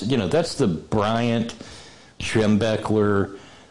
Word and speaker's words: Schembechler: Schembechler, how do you pronounce Schembechler.